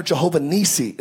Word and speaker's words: Jehovah Nissi: So it says, Moses built an altar and carried the name of it, the Lord is my banner, Jehovah Nissi, is how you pronounce it in the original language.